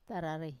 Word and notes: tararai: Wamesa

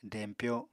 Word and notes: dempyo: apatani

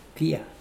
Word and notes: kia: Mokilese

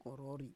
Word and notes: korori: Roon

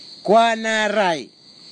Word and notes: kwanarai: kwamera; Tanna

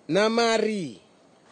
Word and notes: namari: Nafe; Tanna

